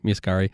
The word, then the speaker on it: Muscari: That's exactly how you pronounce it. You're good. Muscari.